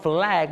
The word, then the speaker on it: flag: องออกเสียงคำว่า flag มี l ชัดเจนนะ